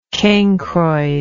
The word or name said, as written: cancroid